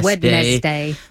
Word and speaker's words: Wednesday: Wednesday. – That's a strange one. – Wednesday is a horrible word in total because it's impossible to spell. It's got a silent D in it. – You'd think it should just be spelled W-E-N-S-D-A-Y, not just Wednesday.